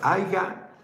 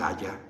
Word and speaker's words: haya: Yo quería decir AYA, pero pronunciaba mal.